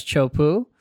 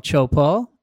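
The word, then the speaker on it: Teahupo'o: We're going like, okay, help us pronounce, you know, what we first heard as Chopu. And then we started saying, no, it's an O, Chopo.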